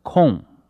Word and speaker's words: Kuhn: 可是我看这个加拿大人杨志英若是他的英文的姓 KUHN 一般读作空，一般读作空若是翻成中文的话，翻成孔，姓孔中国这个孔很了不起姓孔不是很好吗？